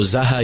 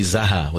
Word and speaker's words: Zaha: So zaha, yazhu. So the proper word would not be Zuha, but it would be Zaha with a fatha. Zaha'un with a hamza at the end.